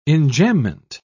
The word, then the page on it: enjambement: British English